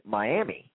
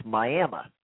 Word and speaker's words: Miami: Now, it's spelled the same way it is down here in the Sunshine State where they pronounce it Miami, but it's Miami.